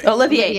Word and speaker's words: Olivier: Olivier, not Oliver.